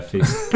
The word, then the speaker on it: Feist: Yeah, Feist.